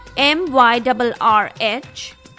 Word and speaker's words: myrrh: Let me spell it for you. M Y R R H. Whatever you want to call it. I think the best pronunciation is myrrh.